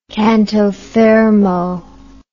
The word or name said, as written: canto fermo